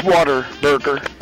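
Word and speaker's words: Whataburger: Or, by the way, for the record, it's water burger. – Okay. – That's how you say it.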